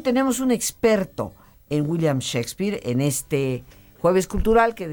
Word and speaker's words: Shakespeare: Pero en realidad no se dice Chaquespeare, se dice Shakespeare.